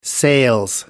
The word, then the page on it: sales: British English